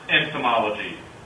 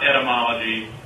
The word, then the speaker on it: entomology: And it's pronounced entomology, not etymology or anthemology.